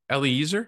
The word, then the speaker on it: Eliezer: And the heir of my house is Eliezer, did I say that right? – Yep.